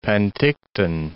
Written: Penticton

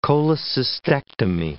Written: cholecystectomy

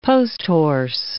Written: post-horse

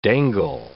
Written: dangle

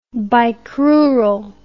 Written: bicrural

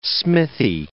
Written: smithy

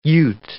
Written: Ute

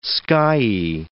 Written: skyey

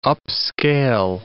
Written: upscale

